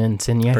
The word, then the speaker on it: Insigne: Bernadeschi, if I said that right, correct. And then Insigne, it also was a recent. – Bernadeschi and Insigne. – Bernadeschi, Insigne, thank you.